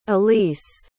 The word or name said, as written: alysse